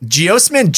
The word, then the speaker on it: geosmin: You're smelling a chemical called geosmin. – You cannot have said that right. – Geosmin, G-E-O-S-M-I-N, geosmin.